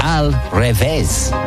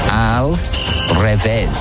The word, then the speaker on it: al revés: Al revés. R-E-V-E, accent S. Al revés. – Revés. – It's hard. – Reverse. – Revés. Like, reverse. Is it reverse? – Okay. It's not spelled like that, but you wouldn't be a million miles away.